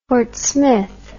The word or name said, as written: fort smith